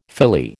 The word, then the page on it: filly: American English